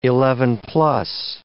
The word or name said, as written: eleven-plus